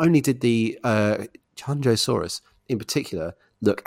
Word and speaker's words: Qianzhousaurus: Qianzhousaurus.